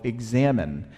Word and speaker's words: examen: It's been new to me over the past 6 months or so, called examine. It's spelled E-X-A-M-E-N, although it sounds like examine, like to examine, like your doctor examines you when you go in for your yearly checkup.